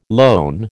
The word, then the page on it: lone: American English